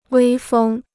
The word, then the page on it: 威风: wēi fēng